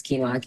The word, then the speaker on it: quinoa: I always pronounce it wrong. It's quinoa, quinoa.